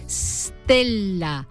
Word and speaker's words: stella: En latín se escribía stella y se pronunciaba con S líquida, es decir, stella, un sonido antipático y difícil de pronunciar para nuestros antepasados, que le plantificaron una E protética por delante. Una prótesis, una muleta, vaya, una vocal donde apoyar esa S.